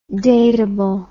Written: datable

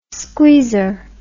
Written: squeezer

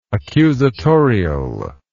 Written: accusatorial

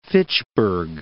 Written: Fitchburg

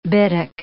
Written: bittock

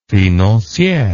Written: financiere

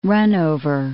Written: runover

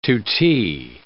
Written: tutee

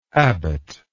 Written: abbotship

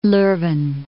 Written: Leuven

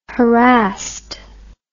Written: harassed

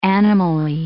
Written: animally

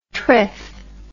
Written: triff

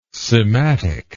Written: sematic

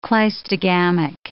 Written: cleistogamic

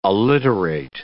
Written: alliterate

